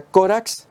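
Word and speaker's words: corax (κόραξ): Y por lo tanto, cuervo en la Septuaginta, miramos ahí, se pronuncia corax y coracos, ambas palabras.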